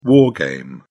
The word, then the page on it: war game: British English